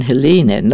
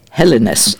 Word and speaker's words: Hellenes: Not to mention Helene, no, Hellenes, it should be pronounced.